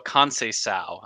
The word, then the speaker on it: Conceição: Francisco Conceição. I've learned to give those C's the S pronunciation there.